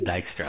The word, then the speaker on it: Dijkstra: But it's Dijkstra.